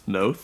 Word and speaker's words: Noth: You didn't even pronounce Noth right. – I'm pretty sure it's Noth. – Noth? – Noth? – Chris Noth? – N-O-T-H? – Yeah. – Noth? – As in Noth.